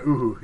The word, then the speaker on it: Uhu: Uhu? – Yeah, Uhu.